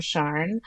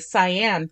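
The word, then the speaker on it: Siân: I can't think of any reservations I have for the name Cyan or Sharn. I think Americans will default to the Cyan pronunciation.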